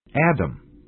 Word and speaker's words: atom: Number one, M-A-T-T-E-R, matter. Matter is often pronounced madder, as in, what's the matter?Number two, A-T-O-M, atom. Atom is often pronounced Adam, as in, the first atom bomb was dropped on Hiroshima. Number three, T-W-E-N-T-Y, 20.